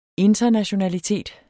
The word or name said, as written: internationalitet